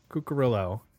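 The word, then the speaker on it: Cuccurullo: Warren, how do you say his last name, people? – Cucurillo.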